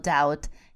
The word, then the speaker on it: doubt: Там есть буковка B, которая пишется, но не читается. Как пишется это слово? How do you spell doubt? D-O-U-B-T. D-O-U-B-T. И вот эта буква B не произносится.